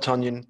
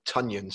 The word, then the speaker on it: Tonyan: Unfortunately, I think Robert Tonyan disappointed for Steve, which I think he was always Tonyan, sorry. Mm. The pronunciation's been corrected, apparently. Apparently everyone called him Tonyan for 2 years.